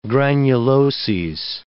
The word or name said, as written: granuloses